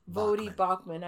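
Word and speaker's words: Voddie Baucham: Bodie Vachman spoke at one. Is that the way? I, did I say his name right? – I think it's Voddie. Voddie Baucham.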